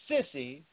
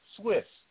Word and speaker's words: Suisse: Credit sissy domino effect far more potent than Silicon Valley Bank. Now, they say Credit Swiss. Okay? I just choose to pronounce it the way I see it. Credit sissy. S-U-I-S-S-E.